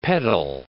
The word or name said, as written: pedal